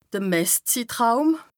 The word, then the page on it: de Messzittraum: Bas Rhin